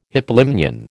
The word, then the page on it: hypolimnion: American English